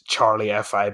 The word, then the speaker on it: CHXRLIEFI: I think it's Charlie FI, but it's spelled CHXRLIEFI.